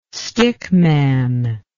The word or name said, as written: stickman